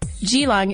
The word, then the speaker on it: Geelong: For instance, Geelong isn't with a hard G like in golf. It's a soft G, like G, that's long.